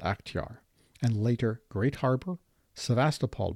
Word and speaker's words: Sevastopol: Now let's focus on our main subject, Sevastopol, also known as Sevastopol. Both pronunciations apparently are acceptable. Quote, "The city of Sevastopol began as a small garrison town on the south coast of Akhtiar Bay.